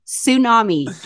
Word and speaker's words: tsunami: It's tsunami, Jonathan. – Tsunami. The T, there you go. – Is silent.